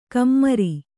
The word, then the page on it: ಖಮ್ಮರಿ: khammari